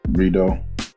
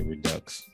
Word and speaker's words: Rideaux: Rideaux. – Rideaux. I was gonna say Redux, but I know it wasn't that, so I was like, let me just ask.